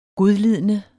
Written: godlidende

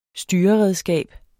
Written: styreredskab

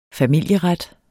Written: familieret

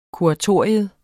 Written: kuratorium

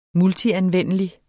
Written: multianvendelig

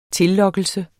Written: tillokkelse

